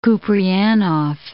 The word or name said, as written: Kupreanof